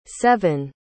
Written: sevyn

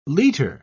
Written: liter

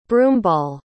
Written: broomball

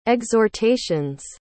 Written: exhortation's

